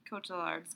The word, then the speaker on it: Cotillard: So he's hanging out with Marion Cotillard's character.